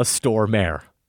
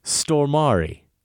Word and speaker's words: Stormare: Peter Stormare is not a Stormare. I love that pronunciation so much, Adam says. It's Stormari. Think first storm, then add Ari, pronounced like the end of Volari. You know the song. Then say it fast. That's pretty much the Swedish pronunciation.